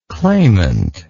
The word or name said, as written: clamantly